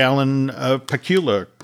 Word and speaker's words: Alan Pakula: So, you know, this is the Alan Pakula.